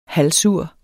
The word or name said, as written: halvsur